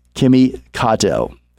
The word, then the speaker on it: Kato: I've also got on the show today joining us Kimi, Kato, formerly a board member, of Universal Japan, and now he is the CEO of Project Asteri.